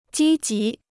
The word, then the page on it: 积极: jī jí